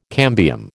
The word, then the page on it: cambium: American English